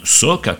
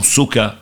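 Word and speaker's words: socca: Attention, on dit bien soca, comme tu l'as très bien dit d'ailleurs, et non soca, qui n'a aucun sens et qui est encore plus affreux à prononcer.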